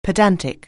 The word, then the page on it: pedantic: anglais